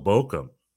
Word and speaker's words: Bochum: VfL Bochum, I believe is how you pronounce it. Right? – Yeah, very nice.